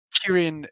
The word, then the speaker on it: Kirin: Kirin is the Japanese pronunciation. The Chinese pronunciation is Qilin. Well, Qilin. – I mean, it's the anglicized version, but yeah, Qilin is the Chinese version.